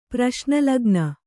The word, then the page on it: ಪ್ರಶ್ನಲಗ್ನ: praśna lagna